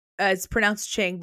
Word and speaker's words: Qing: So Qing is pronounced Qing, but it's spelled Q-I-N-G.